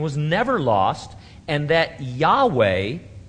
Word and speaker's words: Yahweh: Several early Greek writers of the Christian church testify that the name was pronounced Yahweh.